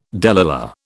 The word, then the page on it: Delilah: British English